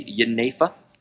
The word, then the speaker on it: Jenever: But the pronunciation in Dutch would be Jenever, which would be with a J. But the pronunciation in English would be Geneva, because it's very difficult to say those words.